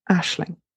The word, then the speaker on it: Aisling: Again, it's pronounced Aisling.